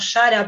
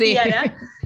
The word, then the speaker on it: Yara: Es una peli que se estrenó hace unos 15 días más o menos, que se llama Yara, se escribe con Y, vendría a ser como Yara para nosotros, pero bueno, para el resto de la humanidad es Yara.